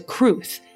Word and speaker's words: crwth: He played the organ and a Welsh string instrument known as a crwth, which is spelled, I kid you not, C R W T H the Welsh language does not mess around.